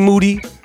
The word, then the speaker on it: Moody: G. Moody, last name rhymes with duty.